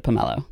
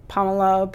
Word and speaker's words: pomelo: I happen to pronounce it pomelo. – I grew up saying pomelo, but pomelo, people say it different ways.